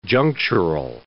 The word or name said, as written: junctural